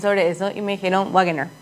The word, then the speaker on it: Wagener: Mira, acá lo que me dice Inteligencia Artificial, la pronunciación correcta de Wagener, o sea, W-A-G-E-N-E-R, de origen alemán, se aproxima fonéticamente a Wagner. Wagner.